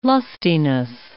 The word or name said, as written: lustiness